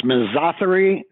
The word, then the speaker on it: misothery: Can you talk to us a bit about the origins of misotheria during this time? – Yes, I think it should be pronounced misothery, sort of rhyming with misogyny.